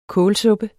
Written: kålsuppe

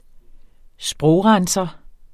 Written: sprogrenser

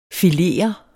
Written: filerede

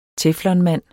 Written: teflonmand

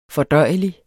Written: fordøjelig

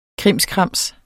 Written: krimskrams

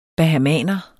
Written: bahamaner

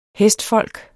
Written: hestfolk

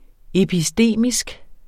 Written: epistemisk

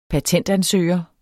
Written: patentansøger

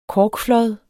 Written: korkflåd

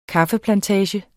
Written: kaffeplantage

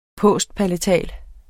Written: postpalatal

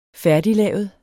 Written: færdiglavet

